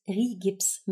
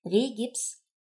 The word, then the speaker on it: Rigips: Die Trockenbauplatte heißt Rigips mit I und P, nicht Regips mit E und B. Wird übrigens ganz häufig selbst in der Fachbranche unter Kollegen im Handwerk falsch ausgesprochen.